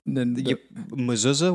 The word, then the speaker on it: mezuzah: Say the mezuzah? What it's called? A mezuzah. – I want to make sure I'm saying it correctly. – Yeah. – Mezuzah.